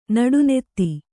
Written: ನಡುನೆತ್ತಿ

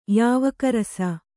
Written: ಯಾವಕರಸ

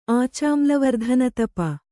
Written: ಆಚಾಮ್ಲವರ್ಧನತಪ

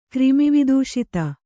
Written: ಕ್ರಿಮಿವಿದೂಷಿತ

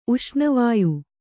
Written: ಉಷ್ಣವಾಯು

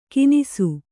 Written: ಕಿನಿಸು

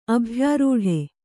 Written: ಅಭ್ಯಾರೂಢ

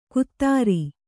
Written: ಕುತ್ತಾರಿ

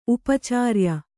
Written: ಉಪಚರ್ಯೆ

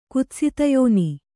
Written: ಕುತ್ಸಿತಯೋನಿ

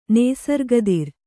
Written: ನೇಸರ್ಗದಿರ್

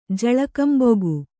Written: ಝಳಕಂಬೊಗು

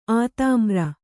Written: ಅದ್ಮರ

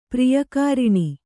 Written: ಪ್ರಿಯಕಾರಿಣಿ

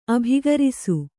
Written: ಅಭಿಗರಿಸು

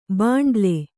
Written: ಬಂಡಲು

